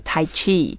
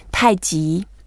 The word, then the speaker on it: Tai Chi: Yeah, I noticed that lots of Westerners, they started getting familiar with the term Tai Chi, and the way they tend to pronounce it is like Tai Chi, which is, it's not wrong, it's just not the way Chinese people would say. So let's show them how to say it properly.